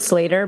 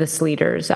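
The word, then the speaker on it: Sleater: But it's technically, we pronounce it Slater, but we're okay with the Sleaters out there.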